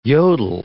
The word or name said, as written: yodel